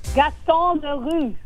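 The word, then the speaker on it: Gaston Leroux: Gaston LaRue. – Yes, and the French pronunciation.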